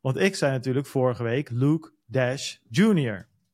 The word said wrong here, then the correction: Dashjr: Ja. – Omdat dat ook zo staat in principe, maar het is Luke Dasher.